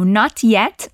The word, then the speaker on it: not yet: A gente pode pronunciar o T se quiser. Not yet. Mas é comum, especialmente no sotaque americano, deixar esse T no ar, assim. A boca faz o movimento do T.